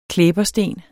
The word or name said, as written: klæbersten